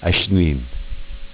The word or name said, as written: Ac'nin